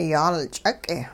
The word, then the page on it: yaalch'uq': hupa